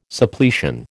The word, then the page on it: Suppletion: American English